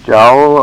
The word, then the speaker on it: jowl: I think you said jewel. – Jowl, all right. – Jowl. – Jowl. Is that how you pronounce it? – J-O-W-L. Spell, I think, jowl.